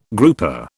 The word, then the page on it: grouper: British English